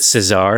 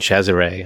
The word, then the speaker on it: Cesare: Most people think it's pronounced Cesare, and that's how I thought it was pronounced for a long time, because that's how it's spelled. But then I watched a documentary on the movie, and they were all saying Cesare, and I was like, oh, that sounds way cooler. So, yeah, well, it's the right. Italian pronunciation.